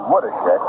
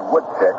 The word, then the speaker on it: Woodeshick: It's hard to say Woodishick, which is the correct pronunciation. You're tend to say Woodshick. Think of how many chucks could a woodchuck chuck if a woodchuck could chuck wood.